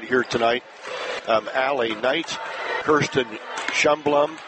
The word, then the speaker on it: Schlumbohm: S-C-H-L-U-M-B-O-H-M. But the accent's on the first syllable. It's Schlumbum.